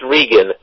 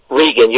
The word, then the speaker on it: Regan: I'm just fine, and Stan, it's Regan. Regan, you've known me for 5 years, and you still mispronounce my name. Please, Dan, it's Regan. R-E-G-A-N.